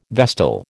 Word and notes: vestal: American English

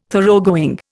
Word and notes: Thoroughgoing: Indian

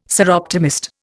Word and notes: Soroptimist: Indian

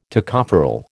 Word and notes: Tocopherol: American English

